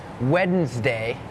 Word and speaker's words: Wednesday: โล่งอกเหรอลูกโล่งใช่ไหมเห็นแล้วถอนหายใจเฮือกว่าเริ่มเลยก่อนเริ่มรายการพี่โบพูดถึงคำว่าอะไรน้องๆจำได้ไหมคะ Silent letters ใช่ไหมคะนั่นก็คือตัวอักษรที่ไม่ออกเสียงแต่อย่างคำนี้ค่ะเป็นคำหนึ่งที่มี silent letters Wednesday has that D W E D it's not wed Wednesday Wednesday D is silent